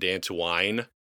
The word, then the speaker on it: Dantooine: I think Carrie Fisher pronounced it Dantooine in the movie because probably didn't have that worked out yet.